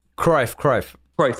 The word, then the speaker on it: Cruyff: Like, and so Johan Cruyff was, or Cruyff, I don't even know how to pronounce it. – Cruyff. – Thank you.